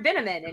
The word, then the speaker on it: Binnaman: Um, you know, one line that I was also secretly glad they kept in when uh, Sylvia shows up and she's like, "This is Mr. Binnaman," and he goes, "Rhymes with cinnamon."